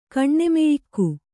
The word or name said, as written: ಕಣ್ಣೆಮೆಯಿಕ್ಕು